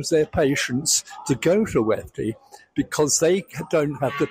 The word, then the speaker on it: WEFTE: WEFDE stands for Water Exercises for the Elderly, and we pronounce it WEFDE, W-E-F-T-E.